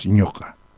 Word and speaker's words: ñoqa: No, lo que pasa es que tú estás pronunciando la K. Yo estoy pronunciando la Q. No estoy pronunciando así, ñoca. No, yo estoy pronunciando ñoca. Entonces esa Q es gutural.